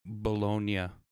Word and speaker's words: Bologna: It's spelled like bologna, but because it's in Italy, they pronounce it in an Italian inflection, so it's like Bologna or something very close to that.